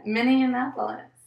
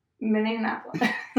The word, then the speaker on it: Minneapolis: Minneapolis. – Mini. Mini. Apolis. – Apolis. – Put them together. – Minneapolis. Minneapolis. – It's not like Indianapolis. I feel like you're trying to make Minneapolis Indianapolis.